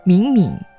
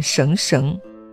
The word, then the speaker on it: 绳绳: 遗儿子孙敏敏兮，敏敏注意读音，不念绳绳。